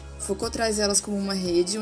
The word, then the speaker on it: Foucault: Se escreve Focaut, mas como é francês, se pronuncia Foucault.